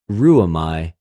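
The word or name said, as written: ῥυομαι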